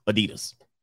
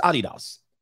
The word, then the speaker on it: Adidas: I don't know if you know, that's how you pronounce Adidas. It's Adidas.